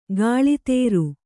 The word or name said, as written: ಕಳಾಧರ